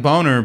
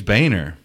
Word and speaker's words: Boehner: It looks like Boner, but he'll tell you it's Boehner.